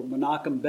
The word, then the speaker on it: Menachem: Manaen also is, today, that name continues in, among Jewish and Hebrew circles, but it's pronounced Menachem. I remember Menachem Begin, the Israeli leader. So it's pronounced Menachem today. It was Manaen back then.